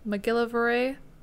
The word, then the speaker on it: McGillivray: How do you say McGillicuddy. – That? McGillivray. McGillivray? – Yeah.